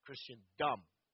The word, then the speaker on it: Christendom: And I would stress the last syllable of that, Christendom.